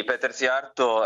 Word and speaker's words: Szijjártó: Chi è Péter Szijjártó, se così si pronuncia? – Sì, Péter Szijjártó è il ministro degli esteri ungherese,